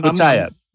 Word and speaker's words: Boutayeb: All right, so I'm going with pronunciation here, okay? – All right, I'm Boutayeb. Yes. – Yeah, Boutayeb, and it's been phonetically spelt for us here.